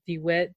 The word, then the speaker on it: DeWitt: And you know what, Dr. John DeWitt, did I say it right? – You did.